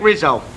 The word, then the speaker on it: Rizzo: Frank Rizzo. – Spell it. – R-I-Z-Z-O. – R-I-Z-Z-O.